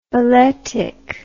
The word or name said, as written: balletically